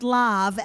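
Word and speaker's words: lav (לב): Your heart, if you look it up, it's the Hebrew word leb, L-E-B, pronounced lav, L-A-V, and it's the birthplace of your emotions.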